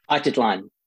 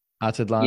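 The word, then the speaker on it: Atitlán: Atitlan. – Atitlan.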